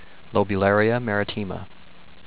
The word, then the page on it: Lobularia maritima: low-bew-lare-ee-a mare-e-tee-ma